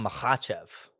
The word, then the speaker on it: Makhachev: So we're emphasizing the second syllable, right? So it's not Makhachev, right? It's Makhachev, right?